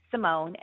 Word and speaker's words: Symone: And so if you have any legal questions that you'd like answered, you are welcome to, you can text me at 855-4, Symone, and I spell it S-Y-M-O-N-E, or you can DM me on Instagram at Symone Redwine.